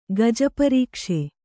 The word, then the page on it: ಗಜಪರೀಕ್ಷೆ: gaja parīkṣe